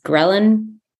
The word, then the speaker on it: ghrelin: It affects your appetite because it affects, I don't know how to say it, it's ghrelin. Ghrelin? I think it's ghrelin. It looks like the word gremlin,